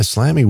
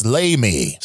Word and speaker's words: Slamy: But it's spelled S-L-A-M-Y, so I always want to read it as Slammy. – Slam? Oh, you're right. Yeah, Slammy would be the more phonetic pronunciation, would it not? – Yeah but it's Amy, and then it's Slamy.